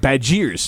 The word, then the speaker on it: Badgers: They are the Baj-airs. They say it, Baj-airs. So B-A-D, Baj-airs.